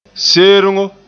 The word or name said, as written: sergo